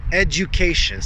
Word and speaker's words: education: I hear the word pronounced all the time, education. Edu, edu, and it's a edu, education. So make that U a full U, education. When you say it fast, it almost has a J sound to it.